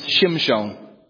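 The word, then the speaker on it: Shimshon (שמשון): In Hebrew, this name is pronounced Shimshon, and it literally means little son, S-U-N.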